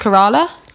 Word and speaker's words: Kerala: I think it's pronounced Kerala.